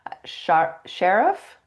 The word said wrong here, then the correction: Sharif: Sharif. – Sharif. Okay.